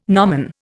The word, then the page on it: gnomon: Indian